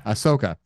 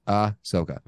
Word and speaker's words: Ahsoka: Ahsoka. Just pretend that first H doesn't exist. It's not. Yeah. It's Ahsoka.